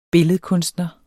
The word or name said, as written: billedkunstner